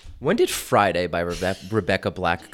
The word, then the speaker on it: 6LACK: But number 94 is Problems by, it's spelled 6LACK, but I know it's supposed to be said Black.